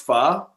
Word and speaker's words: pho: Hey, well, just to wrap this up, do you call it, like, because I know it's pronounced pho, but I can't call it pho because it sounds douchey if I say it correctly.